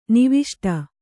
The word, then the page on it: ನಿವಿಷ್ಟ: niviṣṭa